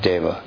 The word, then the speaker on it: dev: What is the Sanskrit root of deva? Do you know? – Div. – D-I-V. And from div through vridhi, D-I-V becomes... D-A-V, pardon me, D-E-V, E is pronounced like A, Dev.